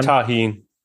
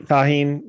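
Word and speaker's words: Tajín: Go to like the Mexican markets and then you get the cup with the salt and the I'm gonna butcher the name. Tajin. – Tajin. – Yep. Tajin. Yeah.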